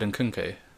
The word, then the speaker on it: Nkunku: There's way too many syllables for two. Yeah. Two halves of the same word. Six letters. – Nkunku. It should be, because you're pronouncing the first half of his name very differently to the second half of his name.